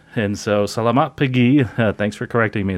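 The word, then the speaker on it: selamat pagi: Uh, is uh, Salamat Paji. Uh, I think that's how you say it. Uh, did I do that right, Len? I hope I said close. Uh, salamat pagi. Pagi.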